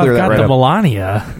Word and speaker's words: Melania: Yeah, if you say, like, Menalia, it sounds really bad. – You just changed the inflection on one of the syllables. – Now it went, before it sounded like an airborne disease. Now it's, when you say it the other way, it sounds like an STD.